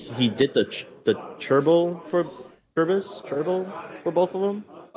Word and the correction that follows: gerbil: The gerbil.